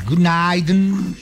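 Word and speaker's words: Günaydın: Se dice buenos días. Yunaidun. – Yunaidun.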